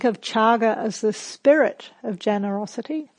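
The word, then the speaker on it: cāga: spelled C-A-G-A. And in Pali, C is pronounced ch, so chaga.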